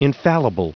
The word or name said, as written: infallible